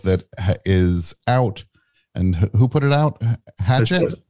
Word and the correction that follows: Hachette: Hachette, yep. – Hachette? Okay. I apologize.